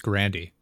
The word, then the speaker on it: Grande: Fun fact, her name is not Grande, it's Grandy. People don't know that though. It's been mispronounced the entire time.